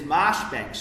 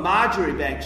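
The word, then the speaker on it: Marjoribanks: And I grew up in Wellington where there's Marshbank Street, and most people now call it Marjorie Bank Street because, John, that's how it's spelt.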